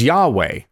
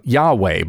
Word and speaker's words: Yahweh: It's YHWH. We don't actually know the right way that is to be pronounced. So we add some vowels in there and we use the name Yahweh.